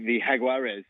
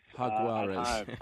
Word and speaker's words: Jaguares: The Jaguares. – Jaguares.